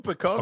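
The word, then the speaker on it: Pucovski: Popovac or Popovich. – Will Pekowski. – I've got that wrong, haven't I? Popovski? – Will Pekowski. – That's the one.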